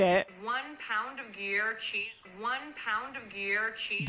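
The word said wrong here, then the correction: Gruyère: Do you understand what you just did? No. Gruyere cheese.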